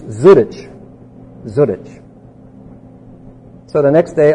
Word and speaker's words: Zurich: He says, oh, no, you're not saying it right. It's Zurich.